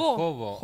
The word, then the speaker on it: hobo: Entonces debería ser pronunciado hobo. – ¿Hobo? – Claro, sí, en castellano como la H no suena.